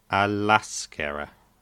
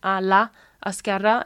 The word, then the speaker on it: a l'esquerra: You say that as one word, right? – Yeah, l'esquerra. Okay, as you know now, hopefully, you remember the two vowel sounds often combine to make that one word. Okay, so instead of saying a la esquerra, it's just a l'esquerra.